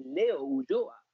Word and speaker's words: Leo Ulloa: How do you pronounce it as an Argentine? My dad said, Leo Ulloa.